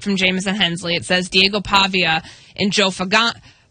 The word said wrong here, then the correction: Fagnano: the quarterback from UConn. I promise I'll get his name right. – Fagnano.